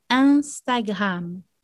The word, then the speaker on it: Instagram: O Instagram é Instagram. Capricha no som nasal. Un.